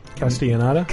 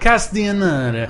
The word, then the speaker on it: Castellaneta: Castellaneta? – Castellaneta.